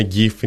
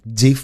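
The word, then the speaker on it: GIF: Η επιθυμία του ιδρυτή ήταν να προφέρετε τζιφ. Mm-hmm. Γιατί ήταν αναφορά στο φυσικό βούτυρο για το οποίο γινόταν ένα λογοπαίγνιο. Γιατί έτσι το ήθελε. Αλλά ναι, καθιερώθηκε να προσφέρεται γκυφ.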